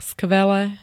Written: skvele

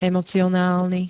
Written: emocionálny

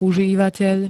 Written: užívateľ

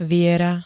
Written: viera

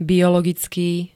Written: biologický

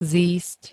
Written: zísť

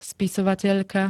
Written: spisovateľka

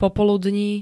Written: popoludní

